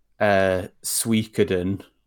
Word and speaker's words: Suikoden: So there was a Kickstarter a few years ago that was basically for this like new Suikoden.